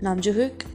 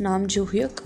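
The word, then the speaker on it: Nam Joo-hyuk: So the male leads are Nam Joo Hyun, Nam Joo Hyuk, I think that's the correct way to pronounce it, Nam Joo Hyuk.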